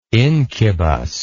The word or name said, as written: incubous